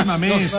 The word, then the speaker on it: Mamés: Ay, mamés. No, mamés. – Un acento. – Mamés. – Ah, ¿qué? – Pronuncia sí. Correcto.